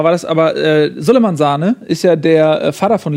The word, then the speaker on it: Sané: Laut äh, Peter Neururer musste er aber Sahne ausgesprochen werden, genau, weil er Leroy sich selber, Sahne.